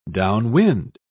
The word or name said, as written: downwind